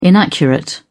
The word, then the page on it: inaccurate: British English